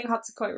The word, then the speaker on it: Hatsukoi: Everyone correct us if we're saying it wrong, but I'm pretty sure it's Kieta Hatsukoi. So, because I remember him saying Hatsukoi in the show, so I know I'm saying Hatsukoi right.